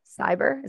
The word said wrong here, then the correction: Khyber: Is it cyber? – Khyber. – Khyber.